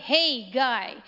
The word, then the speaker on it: Haggai: If you don't know how to pronounce it, it's spelled H-A-G-G-A-I, Hey Guy, and that's all you have to remember.